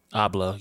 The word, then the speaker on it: Abloh: Is it Virgil Abloh? – Abloh, yeah, Virgil Abloh.